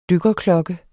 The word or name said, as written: DYKKERKLOKKE